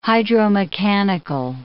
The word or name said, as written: hydromechanical